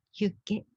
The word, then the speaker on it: hygge: これ聞いたことありますか ?HYGGE でヒュッケと読むようなんですけれどもね。